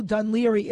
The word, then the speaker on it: Dún Laoghaire: Mark lives, I've been corrected, he's not in Dublin, he's in Dún Laoghaire. And if you don't No, Dunleary. It's spelled like Dangle Harry.